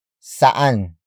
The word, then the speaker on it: saan: Word for word, it's saan kumain si Celine.